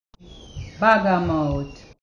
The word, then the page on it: bag–a–mout: bagga–mout